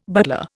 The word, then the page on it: butler: Indian